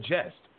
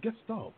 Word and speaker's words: gestalt: I misspoke and mispronounced the word gestalt. I said jest, but it's gestalt.